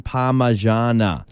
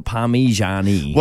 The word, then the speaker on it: Parmigiana: How is it pronounced? Chicken Parmigiana. – Yes. – Not Parmigiani.